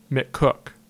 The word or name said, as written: McCook